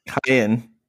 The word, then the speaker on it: Kyan: During the night, at some point, how do you pronounce Kyun? Kyan? Kyan? – Kyan. Kyan. – Kyan. – Like a kayak, but without the ack at the end.